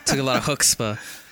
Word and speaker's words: chutzpah: Okay, that took a lot of chutzpah. – Chutzpah. Not chutzpah. Chutzpah. You gotta pronounce it right.